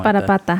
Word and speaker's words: Parapata: find where like the treasure like the paratata is at or something. – Parapata. – Like yeah, paratata. How do you say it? – Parapata.